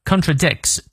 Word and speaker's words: contradicts: If a man 啊，如果一个男的 never contradicts，注意这个重音在 dick 这个音节上。